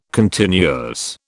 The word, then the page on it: continuous: British English